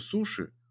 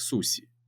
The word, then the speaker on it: суси: В своем мире к японской культуре я не относился практически никак, зная только, что суши правильно произносятся как суси.